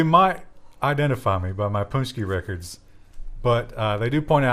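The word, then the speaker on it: pączki: Polish donuts, Punchki. It's not spelled anything what it sounds like.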